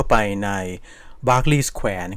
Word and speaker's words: Berkeley: B E R K E L E Y นะครับซึ่งชวนให้เราอ่านว่า Berkeley แต่ว่าจริงๆแล้วเนี่ยคนลอนดอนอ่า